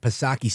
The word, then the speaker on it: Psaki: Of course, that's Jen Pacircleback Psaki, silent P, of course, with Chris Wallace.